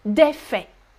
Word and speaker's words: faits: But in the plural form, you do not, do not pronounce the T, right? Un fait ou un fait, singulier. Des faits, pluriel. Des faits. Silent T and silent S.